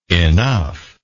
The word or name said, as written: enuf